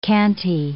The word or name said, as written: canty